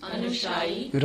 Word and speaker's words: anushayi: Anushayi, anushayi. – Raga. – Raga. – Actually, it's anushayi. Sorry, I said it wrong.